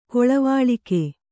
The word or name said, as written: ಕೊಳವಾಳಿಕೆ